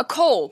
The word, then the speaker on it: col: A col, by the way, C-O-L is how it's spelled, col.